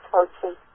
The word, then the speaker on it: Hotze: The last name is Hotze. – Okay. – It looks like it's pronounced Hoxie, but it's Hoxie.